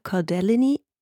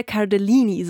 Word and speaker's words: Cardellini: Die Hauptdarsteller David Harbour und Linda Cardellini. – Danke, auf Englisch halt. mit amerikanischem Akzent. Linda Cardellini, so spricht man sie wahrscheinlich, haben dabei jeweils ihren ersten Emmy gewonnen, die beiden.